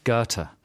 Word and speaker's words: Goethe: Here's a tough one that I only found out recently how to pronounce. A famous German writer. – I know this. – G-O-E-T-H-E. – Goethe. – Right, let's have the real pronunciation. – Goethe.